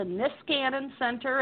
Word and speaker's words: Niskanen Center: You're leaving your teaching work, and you're going to be working at the Nicasin Center. I may have said that wrong. Tell us about your work there at that center. – Yeah, so it's actually the Niskanen Center, and you know, it's like everything else I work for, hard to pronounce.